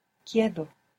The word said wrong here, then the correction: quedo: The E doesn't split here, but good for trying. – Quedo.